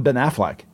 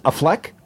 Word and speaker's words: Affleck: Is a fleck a flack a fluke? Wait, how, how how do you say it? I say Affleck. – Ben Affleck. Yeah. – all right, like, like the duck.